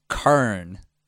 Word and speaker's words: Quorn: What I'm bringing is Kurn, is how I pronounce it. It's not how you say it, but it's corn spelled Q-U-O-R-N.